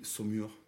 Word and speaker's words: Saumur: en una ciudad que se llama Samur y Saumur, bien, pronunciado.